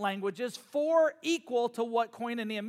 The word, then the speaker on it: koinonia: And that word fellowship is a Greek word pronounced koinonia. Can you say koinonia? Okay, koinonia.